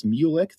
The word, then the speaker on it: Mulick: It's Mulick. So for a visual example, think of mule and then like an animal and then ick, that's disgusting. That's my last name, Mulick.